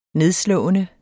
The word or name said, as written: nedslående